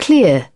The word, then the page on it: clear: British English